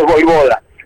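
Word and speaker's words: Vojvoda: Y además confirmando que su apellido es Voivoda. – Claro. Se pronuncia de esa manera.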